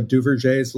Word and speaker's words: Duverger: I think it's French, so Duverger's law.